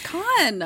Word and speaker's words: Cannes: It's Con.